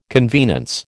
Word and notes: convenance: American English

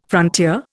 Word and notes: frontier: Indian

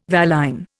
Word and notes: valine: Indian

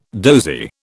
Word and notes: dozy: British English

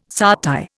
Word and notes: satay: Indian

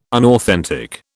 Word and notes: unauthentic: British English

